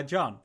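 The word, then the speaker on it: Jon: So I go by John.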